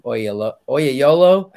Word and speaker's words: Oyelowo: I always say his last name wrong. Oyelowo, I think is how you say it.